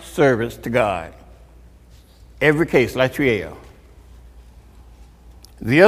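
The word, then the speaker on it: latreia: Latria. – That's right. Sounds right to me. – Latria is found only five times in the New Testament.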